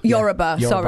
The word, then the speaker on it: Yoruba: Yoruba, which is your... – Yoruba.